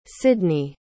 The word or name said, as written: Sydni